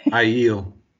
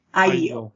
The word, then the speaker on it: Aiel: Aiel. – Aiel.